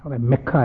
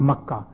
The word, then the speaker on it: Makkah: Mecca has become, not Makkah. The correct pronunciation and the correct word is Makkah Mukarramah. It's not Mecca.